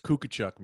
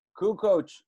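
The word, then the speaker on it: Kukoč: Kukuchuk, man. How about that? – Oh man, Kukoc. It was funny though, watching them confidently say his name wrong.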